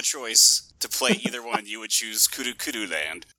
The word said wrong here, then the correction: Kuru Kuru Land: It's pronounced Kuru Kuru Land, and yes.